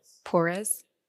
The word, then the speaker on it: Porres: Is that Martin the poorest? The poorest. Porres. You can spell it for me later.